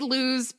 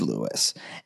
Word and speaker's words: Lewes: Not Lewes. If you say Lewes, people will yell at you even though it's spelled Lewes. – It's spelled L-E-W-E-S. It is Lewes.